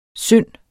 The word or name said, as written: synd